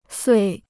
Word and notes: 岁: suì

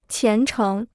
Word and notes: 虔诚: qián chéng

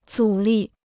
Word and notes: 阻力: zǔ lì

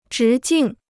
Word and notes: 直径: zhí jìng

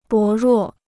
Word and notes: 薄弱: bó ruò

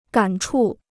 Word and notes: 感触: gǎn chù

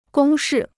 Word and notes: 公式: gōng shì

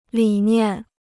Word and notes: 理念: lǐ niàn

